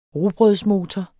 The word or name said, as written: rugbrødsmotor